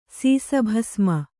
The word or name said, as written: ಸೀಸಭಸ್ಮ